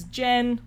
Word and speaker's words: Jen: The main guy's name is Jen, which rhymes with Ben, or Ren.